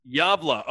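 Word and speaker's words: Gävle: Gavle.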